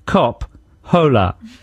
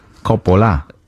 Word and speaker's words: Coppola: The show is called Francis Ford Cop Hola. Yeah? – Yeah, you don't pronounce the H, of course. Coppola.